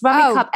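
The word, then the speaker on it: Rummikub: So Fons and I have just started playing a thing called, I don't know how you pronounce it, Rummy Cub. – It's Rummy Cub.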